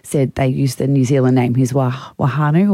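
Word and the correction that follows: whānau: or whatever they say. – Whanu. – Is that how they say it? Yeah, the W-H always gets me.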